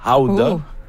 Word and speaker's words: gouda: Ou 3, le gouda, qu'il faut prononcer d'ailleurs, howda. – Howda. – Howda? – Oui, en irlandais. C'est howda. Oui.